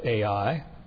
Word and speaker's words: Ai: Just notice, by the way, it is spelled Ai, but pronounced Ai.